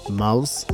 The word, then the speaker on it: Mouse: O produtor tem vindo a apresentar como Mo53. Enganei-me por causa da maneira como se escreve, mas é Mouse.